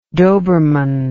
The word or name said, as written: doberman